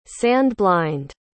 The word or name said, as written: sand-blind